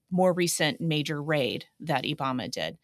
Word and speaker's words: IBAMA: we will um, spell it I B A M A, IBAMA.